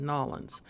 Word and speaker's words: New Orleans: We will have a list of that, and there's nothing wrong with not being able to pronounce New Orleans the preferred way of Nolens. It's a southern thing. I learned this about Nolens.